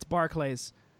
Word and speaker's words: Barclays: Yeah, he mispronounces the name, and then Cena has to sit there and be like, "It's Barclays."